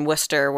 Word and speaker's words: Worcester: And isn't Worcester spelled like Worcestershire? – Yeah. – Yes. Not Worcestershire. That's the sauce.